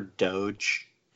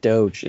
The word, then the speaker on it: Doge: Oh God, not called Doggy Coin. That's not how you pronounce it. – But I prefer Doge. – That's the word. That's how you pronounce it. Doge.